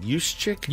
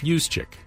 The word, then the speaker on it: Juszczyk: and she saying that right is it Juszczyk? Juszczyk.